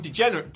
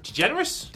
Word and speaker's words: DeGeneres: But yes, Ronda Rousey was on the Ellen DeGeneres? – DeGeneres. – DeGeneres, I always mispronounce that.